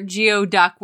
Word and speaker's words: geoduck: It's basically a clam and it tastes just fine, but you will Google how to pronounce it. – Yeah. – Hint, it's nothing like how you say geography. – Well, it or Geoduck, which is a Pokemon.